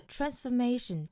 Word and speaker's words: transformation: Ruin 后面的 transformation 音节稍多，它的重读呢是在 A 的位置。